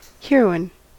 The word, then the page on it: heroine: American English